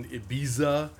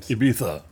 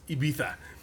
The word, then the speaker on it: Ibiza: Zac Efron is a DJ with dreams of getting outside of the San Fernando Valley and eventually playing in Ibiza and... – Ibiza. Say it right. It's your own country. – I was anglicizing it for the mass audience. Ibiza.